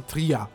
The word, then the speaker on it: Trier: A cidade da Lemoa se chama Trier, que se escreve T-R-I-E-R, que em alemão fala-se Tria.